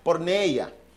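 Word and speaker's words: Porneia (πορνεία): Pero vamos a ver aquí, así se pronuncia. Porneia, que es una palabra en griego y que tiene varios significados, o por lo menos varias traducciones.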